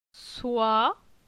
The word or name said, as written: Soi